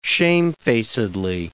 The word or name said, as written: shamefacedly